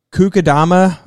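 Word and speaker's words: kokedama: They've got Kukadama moss ball art. I think that's how you say it. It's K-O-K-E-D-A-M-A.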